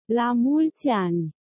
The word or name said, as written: la multi ani